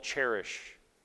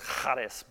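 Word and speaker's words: charis (χάρις): a word called cherish. And it's actually pronounced charis, but I didn't think that sounded as good.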